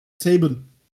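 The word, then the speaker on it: Sabin: Chris Sab Sabian? Sabian? Sabian. Sabian, ja.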